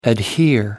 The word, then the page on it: adhere: American English